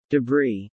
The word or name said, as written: debris